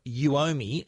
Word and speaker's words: Uomi: Well, the new smart air pump from Uomi, and that's spelled U-O-M-I. It sounds like Uomi money.